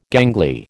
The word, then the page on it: gangly: American English